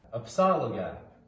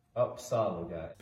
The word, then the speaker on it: Upsaliga: Upsaliga is how it's pronounced. Upsaliga.